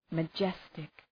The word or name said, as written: majestic